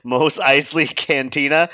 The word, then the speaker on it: Mos Eisley: It was not, why don't you pronounce it again? – Mos Eisley Cantina. Oh. – Okay. Ah. Yeah, you were right. You were right the first time.